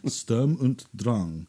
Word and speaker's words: Sturm und Drang: So she kindly obliged and started playing the 2015 album Sturm und Drang. – How do you pronounce that? – Sturm und Drang.